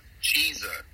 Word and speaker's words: Chizza: It's called a Chizza. And I think I'm saying that right. That's C-H-I-Z-Z-A. – Cheezza. – That's Cheezza? – Yeah, Cheezza.